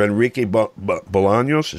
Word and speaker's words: Bolaños: under Enrique Bolaños, is that how you say it? – Yeah.